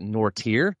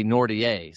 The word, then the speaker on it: Nortier: And Dr. James White gives me a hard time because my last name in the South, we pronounce it Nortier, but it is French. So technically it's supposed to be Nortier.